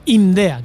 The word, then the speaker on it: IMDEA: Lo he dicho bien, ¿no? INDEA, que es que con esto de las siglas de los centros, INDEA, IMEDEA, IMIDREA. Sí, sí, lo has dicho perfecto, perfecto.